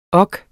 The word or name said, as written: ork